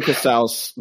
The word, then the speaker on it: Casaus: I know. It's Casaus. It's not Casaus. It's not Cassius. It's not not Martinez. – It rhymes with house, like you're in the house of Casaus, y'all.